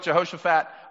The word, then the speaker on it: Jehoshaphat: I need to know, and I verbally need to hear you, how do you pronounce King Jehoshaphat? Okay, good. I heard Jehoshaphat, I heard Jehoshaphat, I heard Jehoshaphat. I'm from Mississippi, so it's like Jehoshaphat, you know what I mean? Like, I'm just making sure. If I pronounce it in a way that isn't how you pronounce it, please forgive me, but I'm going to go with Jehoshaphat.